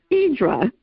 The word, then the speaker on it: Hydra: It makes me want to go to Hydra, which in Greece they pronounce it Hydra, which is interesting.